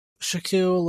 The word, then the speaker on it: Shaquille: And they have Shaquille Leonard.